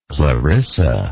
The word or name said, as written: clarissa